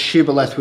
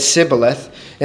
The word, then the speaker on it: Shibboleth: So there was a people who pronounced Shibboleth with a sh, and the other people was unable to produce the sh sound, and they said Sibboleth, and that was the test to find out if you're one of ours or not.